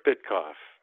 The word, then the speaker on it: Bitkoff: Bitkoff, Stewart Bitkoff.